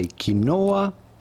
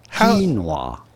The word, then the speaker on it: quinoa: You do want to stress on the first syllable rather than the second one. Do not say quinoa, but rather quinoa.